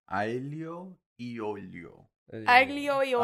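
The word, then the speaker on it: aglio e olio: I really like the Ailio Yolio episode. – Ailio Yolio. – Aglio Yolio.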